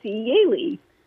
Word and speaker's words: Jelly: Her first name looks, since we're talking about how you pronounce things, her first name looks exactly like Jelly, J-E-L-L-Y. And she was Hungarian, so it's Yeli.